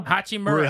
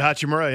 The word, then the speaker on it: Hachimura: Hachimura. – Hachimura, yeah.